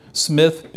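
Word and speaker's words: Psmith: From his school days to his young adulthood, Smith, that's P. Smith, the P is silent,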